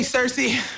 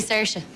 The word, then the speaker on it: Saoirse: Hey, Saoirse. Have a great show. – Thanks, Leslie, but it's actually Saoirse. – Sushi? – No, it's Saoirse, like inertia.